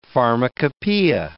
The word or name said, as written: pharmacopeia